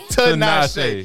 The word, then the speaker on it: Tinashe: Say it like Tinashe? Tinashe? – No, it's Tinashe.